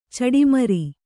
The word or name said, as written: ಚಡಿಮರಿ